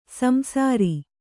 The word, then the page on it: ಸಂಸಾರಿ: kannada